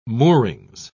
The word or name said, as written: moorings